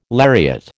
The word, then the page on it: lariat: American English